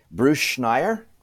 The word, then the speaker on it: Bruce Schneier: Bruce Schneier rhymes with frequent flyer, and I am a fellow and lecturer at the Harvard Kennedy School, among other things.